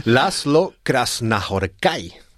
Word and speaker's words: László Krasznahorkai: Te lo voy a pronunciar, aunque no sé si lo entiendas, László Krasznahorkai.